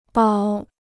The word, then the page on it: 保: bǎo